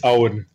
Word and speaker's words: Aoun: Is it Ayun? – It's Owen. Yeah. – Oh, well, it's A-U. How do you spell it? A-U-O-N? – Yeah, A-O-U-N.